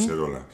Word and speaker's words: Collserola: Y, por ejemplo, como has dicho, nos vamos a Barcelona, al tanatorio de Collserola. No sé si lo digo bien. – Collserola, sí.